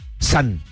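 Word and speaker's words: san (三): En japonés son 3 rayitas horizontales y se pronuncia san.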